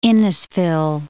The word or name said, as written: Innisfil